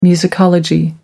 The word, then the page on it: musicology: American English